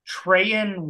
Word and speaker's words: Treyaun: Treyan Webb? T-R-E-Y-A-U-N. – Yeah, sounds right.